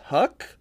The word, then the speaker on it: Huyck: written by Willard Huck. H-U-Y-C-K, yuck. So pronounce yuck. That how you will.